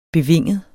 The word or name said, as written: bevinget